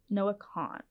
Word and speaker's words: Noah Kahan: Next, Noah Kahan. Is that how you say his name? – Yeah. – Noah Kahan. Okay. It looks a little more complicated than that, but good with me.